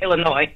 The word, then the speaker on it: Illinois: I want to tell the Envy, the S is silent in Illinois. I'm from Illinois. The S is silent. We don't pronounce Illinois.